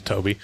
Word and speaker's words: Tobe: Is it pronounced Toby or Tobe? – I don't know. – Or Tubi? – I don't know. Hopefully Toby, because otherwise we're going to look like fucking idiots. – I'm pretty sure it's Toby, but it's spelled T-O-B-E. So it could be Tubi or Tobe or Toby. It's Toby. Anyways, Toby Hooper, when he was editing this film, he was trying to get a PG rating.